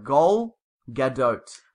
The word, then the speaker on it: Gal Gadot: Gadot, G-O-D-O-T-E. – Oh. – Because the A's are pronounced as in short O's. So it's like Gaul, Gadot.